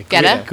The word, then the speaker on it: Guetta: Guetta.